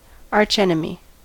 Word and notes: archenemy: American English

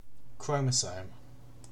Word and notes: chromosome: British English

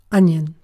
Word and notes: onion: British English

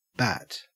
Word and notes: bat: Australian English

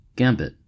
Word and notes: gambit: American English

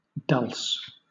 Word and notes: dulse: British English

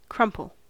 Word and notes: crumple: American English

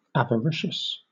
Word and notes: avaricious: British English

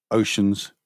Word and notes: oceans: British English

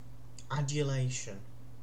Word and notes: adulation: British English

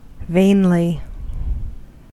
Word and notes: vainly: American English

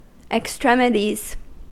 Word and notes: extremities: American English

